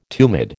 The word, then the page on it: tumid: American English